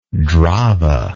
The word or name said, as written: Drava